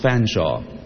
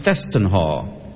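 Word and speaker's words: Featherstonehaugh: Like, for example, there's a name, Featherstone Haw. It's pronounced Fanshawe. Or it can be pronounced Festenhaw. Or Fessenhay. Or Firstenhaw. And the list goes on.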